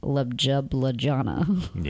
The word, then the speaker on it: Ljubljana: L-J-U-B-L-J-A-N-A, which looks to me like Ljubljana. – Yeah, I was lost. – It's Ljubljana.